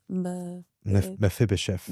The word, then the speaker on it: Mephibosheth: It's Mephibosheth.